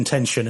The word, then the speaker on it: Haute Tension: What but Haute Tension is H-A-U-T-E, and then Tension is the same. It's just pronounced Tension because it's French.